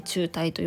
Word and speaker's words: 紐帯: 中帯紐帯って書いて中帯と読むんですけれどもまあつながりというふうにイメージしてもらえたらいいと思います、えー、弱い中帯